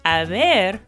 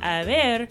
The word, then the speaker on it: haber: First, I want to point out that haber is actually spelled H-A-B-E-R, or H-A-B-E-R. And as you know, the letter H in Spanish is silent unless it comes after the letter C.